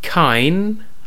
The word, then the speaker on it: Kine: Kine. I hope I'm pronouncing that right. It's K-I-N-E. – Yeah, sounds about right.